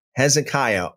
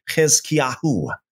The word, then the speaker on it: Hezekiah: and Hezekiah, or in the Hebrew way of pronouncing it, it'd be Hezekiahu, kings of Judah, and which he saw regarding Samaria and Jerusalem.